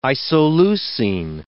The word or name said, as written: isoleucine